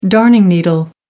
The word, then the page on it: darning needle: American English